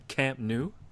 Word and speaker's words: Camp Nou: Is running back going to be a What's that Camp Nou? Did I pronounce it correctly, cuz I mispronounced everything that's foreign? – No, that's pretty good.